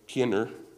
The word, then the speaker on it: Kenner: I think his name is pronounced Kenner, K-E-N-N-E-R, is a commentator that I use.